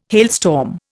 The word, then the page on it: hailstorm: Indian